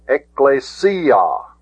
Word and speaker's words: ekklesia: It isn't pronounced ekklesia. I've heard people pronounce it that way, but notice the accent is on the sigma iota. Ekklesia.